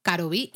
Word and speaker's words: karubi: viene además de la palabra coreana galbi, en este caso, pues pronunciada japonesamente. – Exacto, karubi.